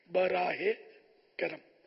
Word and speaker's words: براہ کرم: ہمارے ہم وہ لکھتے ہیں براہ، یا کر کے حمزہ لگاتے ہیں، یہ غلط تلفظ ہے، بلکہ صحیح تلفظ ہے بے رے الف ہا ہے، اس کے نیچے زیر، مدینہ کے آخر میں جو ہے آتی ہے نا، وہ اس کے نیچے زیر ہوا، براہ کرم.